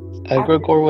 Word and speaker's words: egregore: Egregore with an E.